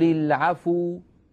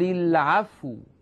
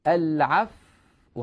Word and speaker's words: العفو: حتى لا تضم الفاء يعني حتى لا يقول القارئ قل العفو هذا الذي أفعله الآن خطأ قل العفو ضممت الفاء هذا سهو يجب أن أفعل هكذا قل العفو العفو أحافظ على سكون الفاء ثم أنطق الواو قل العفو انتبهنا إذن الصواب قل العفو